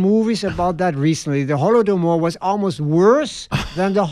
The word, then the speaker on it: Holodomor: Holodomor, that's the correct pronunciation.